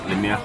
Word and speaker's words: Lemire: Lemire. – Lemire. Esa es la pronunciación correcta.